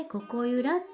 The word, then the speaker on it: 心夢響: 心に夢そして響くという漢字を書いてここゆらって読む